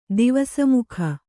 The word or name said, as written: ದಿವಸಮುಖ